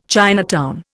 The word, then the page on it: Chinatown: Indian